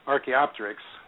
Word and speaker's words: Archaeopteryx: So going back to some of the other points you made, though, Archaeopteryx, which is how that's pronounced, that's a transitional species.